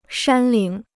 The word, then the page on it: 山岭: shān lǐng